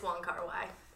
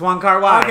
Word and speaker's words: Wong Kar-wai: I'm here to confirm it's Wong Kar-wai. – It's Wong Kar-wai.